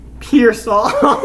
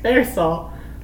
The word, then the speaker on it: Pearsall: What's Ricky Pearsall. – Dude, bro. – He's probably not even saying it right. – Look. Pearsall.